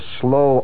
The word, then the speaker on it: sloe: Helen Kubelik, she of the high Slavic cheekbones and the slow eyes, that's spelled S-L-O-E, the slow berry. You've heard the term, haven't you? Her eyes were passions, pools of slow passion, S-L-O-E.